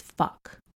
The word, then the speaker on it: phoque: Do you know what seal is in French, by the way? It's fuck. You spell it P-H-O-Q-U-E.